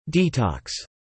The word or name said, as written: detox